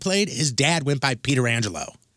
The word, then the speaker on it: Pietrangelo: And he goes, my name is Alex Pietrangelo.